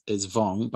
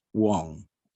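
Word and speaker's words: Vuong: So the anglicized pronunciation is Vong, but I looked it up and it's Wong.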